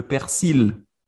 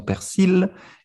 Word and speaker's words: persil: Euh, un autre grand classique, c'est le persil ou le persil. Dans le sud, par exemple, nous disons persil, et dans le reste de, France, ils disent euh, le persil, prononce pas le L.